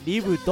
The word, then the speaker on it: LIB.: お送りしてる曲はリブで警告、ここで 2 曲続けてお送りしたリブさんについて簡単にご紹介したいと思います。ガールズユニット LIB. と書いてリブと読みます。